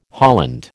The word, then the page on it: holland: American English